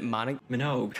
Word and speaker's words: Minogue: How do you say her last name? Is it Minogue? – Kylie Minogue. I never knew that's how you said her last name.